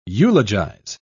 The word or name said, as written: eulogize